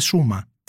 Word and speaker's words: Sharma: Μα και έρευνα να κάνει τώρα ότι οι αδερφέ κατάγονται από τη Βόρεια Ινδία γιατί έχουν το επώνυμο ε, Σούμα, δηλαδή γράφεται Σάρμα, S-H-A-R-M-A, αλλά προφέρεται Σούμα, που είναι ένα ξεκάθαρα βόρειο ε, επώνυμο.